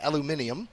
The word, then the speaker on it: aluminium: We're picking up, you know, all of you guys, I think you believe you pronounce it aluminium.